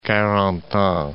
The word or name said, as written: Carentan